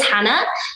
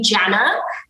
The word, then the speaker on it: Jana: My name is Jana. It's spelled with a J, which can be confusing. People tend to call me Jana, which I always correct. It's like a Spanish J, so it's like jalapeno.